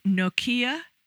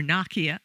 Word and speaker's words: Nokia: If you have that same complaint about smartphones, the new Nokia or Nokia. I don't know how you pronounce it. – Yeah, I've always said Nokia.